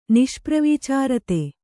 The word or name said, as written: ನಿಷ್ಪ್ರವೀಚಾರತೆ